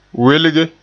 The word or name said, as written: welge